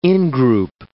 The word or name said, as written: in-group